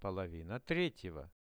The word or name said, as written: polovina 3-ego